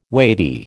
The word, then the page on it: weighty: American English